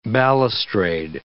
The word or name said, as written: balustrade